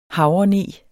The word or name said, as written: havreneg